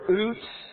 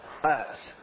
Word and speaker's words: Utz: Is that UTS or OOTS? UTS. Okay, I want to make sure I say it right.